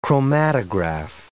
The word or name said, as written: chromatograph